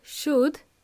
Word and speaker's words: should: The L is silent. So the word should, spelled as in S-H-O-U-L-D, but we don't pronounce L. It's silent, okay?